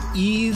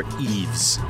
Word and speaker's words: Yves: This is from Eve Toomer, or Eves. It's spelled Y-V-E-S, so however you want to do it, if you want to be more European about it,